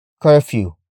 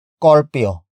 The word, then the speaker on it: curfew: Some Tagalog speakers would pronounce the word as corpio. Corpio. Corpio. Or carpio. Carpio. Carpio.